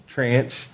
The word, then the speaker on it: tranche: Then they have a local ARPA grant funds, and they have name of the account, first tranche of funding, second tranche, I'm not sure how you pronounce that. – Tranche.